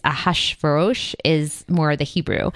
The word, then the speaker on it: Ahasuerus: The kind of anglicized way is King Ahasuerus, which is probably what we'll be using.